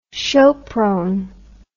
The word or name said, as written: sopron